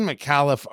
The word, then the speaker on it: Micallef: I'd seen McAuliffe around. I didn't know that was actually how you pronounce it. M-I-C-A-L-L-E-F.